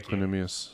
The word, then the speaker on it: Equanimeous: How do you pronounce it? – Equanimeous. – Okay, thank Equanimeous. – You. Yeah. I've never tried to pronounce it. I've just saw E, period, St. Brown. – Equanimeous St. Brown.